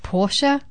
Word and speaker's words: Porsche: But I found out recently that Porsche is actually pronounced Porsche. – Yeah, and it's Porsche. – I thought it was Porsche for the last 14 years of my life. – No, it's Porsche.